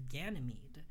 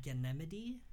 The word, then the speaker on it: Ganymede: I'm not sure if that's the way you pronounce his name, Ganymede. It's spelled G-A-N-Y-M-E-D-E. Mm. I've also heard it pronounced Ganymede, which sounds really cool, but I think the popular pronunciation is Ganymede.